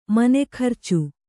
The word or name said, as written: ಮನೆಖರ್ಚು